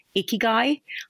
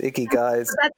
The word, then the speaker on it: ikigai: I mean, I like the, I don't know if you've ever come across the Japanese concept of ikigai, I-K-A-G-A-I. There's a couple of ways to pronounce it, but I know a few women who've met them in bars, ikigais. – That's exactly how I remember how to say it.